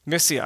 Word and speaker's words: Mysia: I'm just really butchering that word, right? I'm going to go to a doctoral candidate. How do you say that word? Mysia. All right, there we go. I got it.